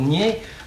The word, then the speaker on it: Nied: Qui s'écrivent N-I-E-D, mais qui ne sont en pays de langue française et les gens du pays disent nier. Mais, alors, ils vous disent même, vous prononcez ça comme pied.